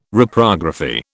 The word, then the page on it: Reprography: British English